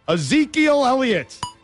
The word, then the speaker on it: Ezekiel Elliott: Ezekiel Elliott, did I say it right? – Yeah.